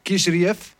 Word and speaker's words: Kisriev: Kyle Dalkus tegen, en moet ik het goed zeggen, alias Kap Kis Kisriev. Zeg ik dat goed, Marcel? – Ja, Marcel. Ja, man.